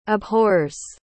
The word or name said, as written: abhors